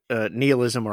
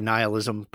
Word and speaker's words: nihilism: It's interesting because you keep using the word nihilism, or I say nihilism, but both are correct pronunciations.